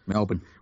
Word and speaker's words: Melbourne: M-E-L-B-I-N? – Melbourne.